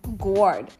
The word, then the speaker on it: gourd: And the festivities end with reading of the testament and burning of the devil and burying of the guard. Gourd. I think I pronounced that right. G-O-U-R-D. Okay.